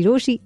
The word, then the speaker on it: Rochie: मेरा नाम है मिर्ची रोशी, नाम की स्पेलिंग है ROCHIE, एंड ऑनेस्टली जिसके पास लव है ना उसके पास सब है, and I love you loads.